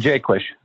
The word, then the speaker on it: Jaquish: Savannah Jaquish, I believe is how you say it, or is it Jaquish? – Jaquish. – Jaquish, yep. Savannah Jaquish, another Southern California girl that went to LSU.